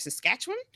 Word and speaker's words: Saskatchewan: So I am so pleased today to welcome my three guests, Dr. Chris Clark from the University of Saskatchewan.